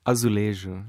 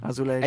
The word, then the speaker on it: Azulejo: Azulejo. – Azulejo.